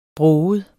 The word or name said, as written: broget